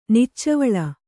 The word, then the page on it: ನಿಚ್ಚವಳ: Kannada